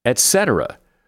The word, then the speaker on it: etc.: Etc. Pronounce etc. exactly how it is spelled. The first syllable is et, not ek.